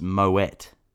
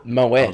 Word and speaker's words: Moët: Surprisingly, it is pronounced with a hard T and not a silent T. So, it's Moët. – Well, the dog's barking now. Moët.